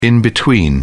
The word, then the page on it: in-between: British English